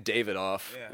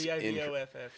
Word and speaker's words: Divoff: I don't know how to say it. – I kept saying David off, and that is Divoff. So yes. Divoff, I guess.